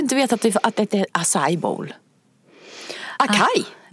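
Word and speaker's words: açaí: Acai heter det. – Acai heter det.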